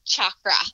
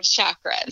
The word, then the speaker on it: chakra: Is it chakra or chakra? I can't say it. – You know, the traditional like pronunciation is chakra. – Chakra. – But you hear everyone in you know, the West these days saying chakras.